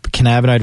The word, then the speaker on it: cannabinoid: Is it cannabinoid? – Cannabinoid receptors.